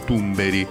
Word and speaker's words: Thunberg: Sì, è una puntata Thunberg diciamo, o Tumberi, come pronuncia chi, chi, chi sa lo svedese.